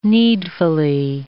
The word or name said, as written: needfully